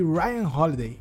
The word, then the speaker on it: Ryan Holiday: Meu primeiro foi O Ego é Seu Inimigo e depois eu li O Obstáculo é Caminho, todo de Ryan Holiday.